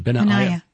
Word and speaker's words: Benaiah: Yeah, I think that's probably better pronunciation. – Benaiah. – I know I had it right the first time. – Mm-hmm. – Sweet. – Just think of that AI as a diphthong.